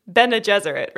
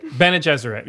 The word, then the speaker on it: Bene Gesserit: Yeah, I think you just did. Bene Gesserit, right? – Bene Gesserit, yeah.